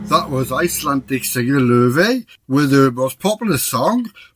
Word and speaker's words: Laufey: In true, like, clean Icelandic, it's pronounced Laufey.